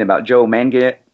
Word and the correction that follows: Manganiello: How do you say it? Joe Mangiello. Uh, Manganiello. Joe, yeah, Manganiello.